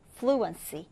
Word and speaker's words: fluency: 첫 음절에 강세를 두고 fluency.